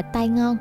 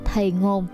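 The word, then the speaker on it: Tai Ngon (堤岸): Người Hoa cho đắp thêm bờ kinh cao ráo và kiên cố hơn, gọi vùng đất này là Tai Ngon hay Tin Gan, mà theo Hán Việt là Đề Ngạn. Đề Ngạn phát âm theo giọng Quảng Đông, nghe ra là thầy ngồn hay thì ngon.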